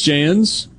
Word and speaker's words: Jans: New head basketball coach, Chris Jans. Jans, Jans, Jans. We'll go with that. Jans, what he Jans? The soft J.